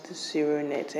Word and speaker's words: ZeroNet: E-T, ZeroNet, pronounced ZeroNet.